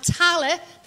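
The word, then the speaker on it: Tallaght: And alongside a fantastic and faithful team, we are currently in the process of launching D24 Church in our hometown of Talla, that's how you pronounce it, Talla, in Dublin.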